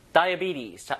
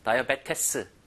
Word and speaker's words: diabetes: 다이비티즈. 자, 요거 읽어볼게요. 자, 스펠링과 실제 읽는 게 너무 달라요. 다이어베테스. 이게 아니라, 다이비티즈.